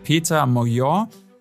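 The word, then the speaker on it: Péter Magyar: Sein Konkurrent Peter Mojor, so habe ich das aus der Tagesschau gelernt, so spricht man das wohl aus, hat mit seiner Taoiseach-Partei einen Erdrutschsieg feiern können.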